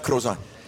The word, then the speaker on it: croissant: Croissant. You don't pronounce the T. Croissant.